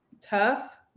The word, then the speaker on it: tough: Tough. That's an F.